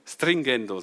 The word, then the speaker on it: Stringendo: Und vorhin beim Einsingen hat Peter ein Wort gesagt, das habe ich zum ersten Mal gehört, Stringendo, war das richtig gesagt? Stringendo, so heißt das.